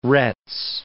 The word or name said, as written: Retz